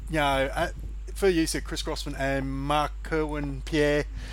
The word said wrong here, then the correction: Jean-Pierre: Jean-Pierre. – Yeah, sorry. – I hope I haven't butchered that.